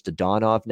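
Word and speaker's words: Dadonov: I think he's changed his pronunciation from what I've heard in the preseason. One of those guys that's changed his pronunciation, but I think it's Dodonov now for the Vegas Golden Knights.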